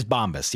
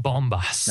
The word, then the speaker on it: Bombas: And we're positive that's how it's pronounced. – It is Bombas. Yes. – It's not Bombas. – No, it's Bombas.